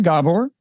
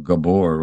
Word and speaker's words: Gabor: Actually, people most often mispronounce my first name. They call it Gabor rather than Gabor.